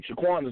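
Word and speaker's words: Saquon: Shaquan is his first name, correct? – Right.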